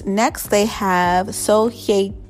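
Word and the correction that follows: Song Hye-kyo: I always mispronounce her name, but it's Song Hye Gyo.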